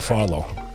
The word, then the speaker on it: Farlow: And I look forward to following up with Rob Farlow.